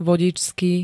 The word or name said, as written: vodičský